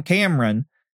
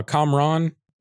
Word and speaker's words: Kamran: so hard to not call him Cameron. You know what I mean? And pronounce his name correctly. – Kamran. – Kamran, yeah.